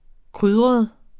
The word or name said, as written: krydret